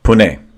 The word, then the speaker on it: Pune: It is near the Mumbai, Pune. – Pune. Is that how you say Pune? It's spelled P-U-N-E, right? Pune? – Yes.